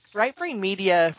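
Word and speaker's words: Writebrain Media: Rightbrain Media, which is spelled W-R-I-T-E. It wouldn't be obvious to somebody just hearing me say that.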